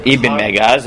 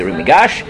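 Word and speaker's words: Ibn Migash: Now, he has a student named Rabbi Yosef Ibn Megaz, as Sephardi, well, the Sephardim will say, or Ashkenazim, who will mispronounce it, will say the Rumigash.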